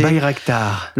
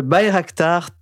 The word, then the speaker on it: Bayraktar: Le Bayraktar. – Le Bayraktar TB2.